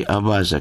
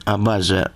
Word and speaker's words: абаза: Но поскольку сам термин «абаза» в абхазском языке неизвестен, то произошел курьез. Они стали использовать его, ставя ударение не на тот слог, не «абаза», а «абаза».